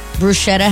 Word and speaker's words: bruschetta: Bruschetta has a hard C. Bruschetta. It's bruschetta, not bruschetta.